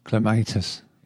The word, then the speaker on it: clematis: We've also got three listeners' questions this month, one on how to protect clematis from frost, or clematis. How do you pronounce it? – Clematis. – Yeah, I say clematis, but I've heard a lot of people say clematis.